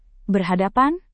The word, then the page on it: berhadapan: Indonesian